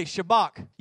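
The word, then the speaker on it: Shabach: Shabak. You kind of, if you want to say it right, you got to do the like you got a popcorn kernel stuck in the back of your throat.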